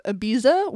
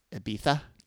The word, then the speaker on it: Ibiza: Shannon, I think it's pronounced Ibiza. – Is that? – Ibiza. I don't know. – It is.